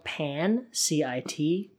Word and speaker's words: pancit: It's spelled pan, C I T, but it's almost pronounced like pronounced like P U N S E A T, so pancit.